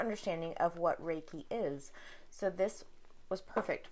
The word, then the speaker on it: Reiki: But I know, so, if anything, I know that it's pronounced Reiki. – Reiki? – Reiki, yeah. – Not Reiki. – Like, okay. No. – Reiki. Okay, perfect. Thank you for that, because I would have been saying that wrong the whole time.